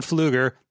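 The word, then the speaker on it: Pflueger: From Sean Fluger, the silent P, who earns himself an extra credit, redeemable for an extra hot, great mini topic or Dave Forsening of his choosing.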